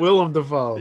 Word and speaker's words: Willem Dafoe: Willem Dafoe was spotted on the Spider-Man 3 set. – Willem Dafoe. – Yeah, I know. Right when I said William, I'm like, oh, no.